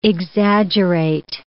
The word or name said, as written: exaggerate